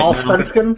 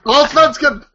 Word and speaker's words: Allsvenskan: Right, Allsvenskan? – The Allsvenskan? Say it. – Allsvenskan!